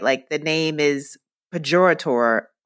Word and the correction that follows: pejorative: How do you say that? – Pejorative. – Pejorative, but it's still a cute name.